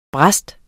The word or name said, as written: brist